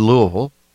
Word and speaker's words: Louisville: But I elected to go to Louisville, to uh, Minneapolis.